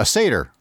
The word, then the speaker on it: satyr: I think I remember them pronouncing it satyr in The Last Unicorn. So that's kind of okay. That's kind of what I go with. – Yeah. Okay. A satyr.